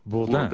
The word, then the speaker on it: Bourdain: you've been much on television in recent years, Anthony Bourdain. And you insist on the American pronunciation, Bourdain, though it's a French name and Yeah. – should be pronounced Bourdain.